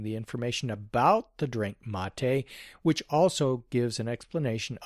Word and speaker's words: Mate: Concerning the pronunciation of M-A-T-E, I believe it should be pronounced the way Larry used to say it, mate.